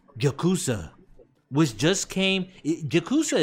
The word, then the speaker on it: Yakuza: True, but first off, if you're gonna say it, say it right. It's Yakuza.